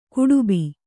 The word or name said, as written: ಗಡುಬ